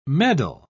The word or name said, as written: meddle